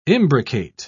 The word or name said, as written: imbricate